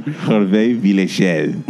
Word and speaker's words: Hervé Villechaize: Hervé Villechaize.